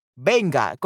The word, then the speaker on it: venga: So this word rhymes with tenga. This word rhymes with tenga. Venga, correcto, venga. Correcto. Yeah, so very, very close to tenga. So quiero que Igor venga a la tenga, a la tenga, a la tenga.